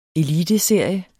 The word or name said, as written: ELITESERIER